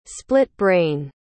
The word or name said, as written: split-brain